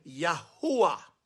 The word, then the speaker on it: Yahuwah: But when I'm referring to him, I want to refer to the Father by his actual name, Yahuwah.